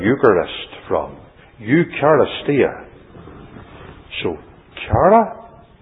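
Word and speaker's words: chara (χαρά): The Greek word for joy is chara, or I'll say chara.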